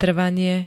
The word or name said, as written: trvanie